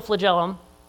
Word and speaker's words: flagellum: He said, the mouse trap and the bacterial flagellum. Did I say that right? Anybody know flagellum? – Yeah.